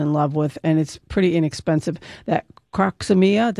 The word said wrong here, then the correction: Crocosmia: did I say that right? Croxomia? – Crocosmia. – Crocosmia, okay.